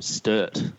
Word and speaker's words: Sturt: So, Sean, you I believe you played for Sturt. I hope I pronounced that correctly. – Yeah, that's right.